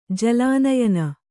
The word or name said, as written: ಜಲಾನಯನ